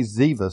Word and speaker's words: Ziba: And I say Ziba, if I say Ziva sometime, the B is to be pronounced as a V, so I'm not just being wishy-washy. I just have not gotten in the habit of saying it as his name really is.